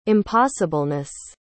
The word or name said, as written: impossibleness